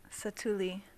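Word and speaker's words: Satu'li: And if it's anything like the Walt Disney World app, which was great for us, especially when we were at Pandora for Santuli. Ready? Is that how you say it? – Satuli. – Satuli. – My bad. Satuli Canteen.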